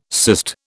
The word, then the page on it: cyst: British English